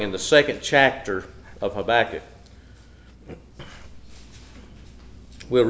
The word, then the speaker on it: Habakkuk: I pronounce it Habakkuk.